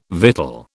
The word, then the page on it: victual: British English